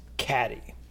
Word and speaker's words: Cady: C-A-D-Y. You know. It is pronounced caddy per Basketball caddy.